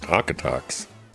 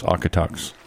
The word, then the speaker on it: Okotoks: How do you know it's pronounced Okotox? – Because it says right there, Okotox, O-K-O-T-O-K-S, Okotox.